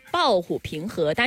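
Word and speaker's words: 暴虎冯河: 抱虎平和中的平和是什么意思？ – 不知道，我都没听说过这个成语。 – 抱虎平和，但是平这个字呢，它写是姓冯的那个姓，它是多音字。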